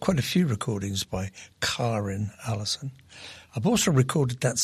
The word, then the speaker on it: Karrin: Hey, that was Karen Allison. Now, you notice I'm saying Karen, but it's spelled K-A-R-R-I-N, but it's not Karen. She calls herself Karen Allison.